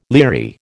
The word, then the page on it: leery: American English